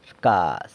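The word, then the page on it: rcaz: San Lucas Quiaviní Zapotec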